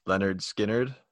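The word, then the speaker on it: Lynyrd Skynyrd: Right below that at 381, we have Leonard Skinner, pronounced Leonard Skinner.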